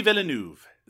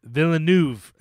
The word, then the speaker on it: Villeneuve: Denis Villeneuve. – Villeneuve. So Villeneuve, I'm okay with. It's the adding the V on the end that I got to work on. So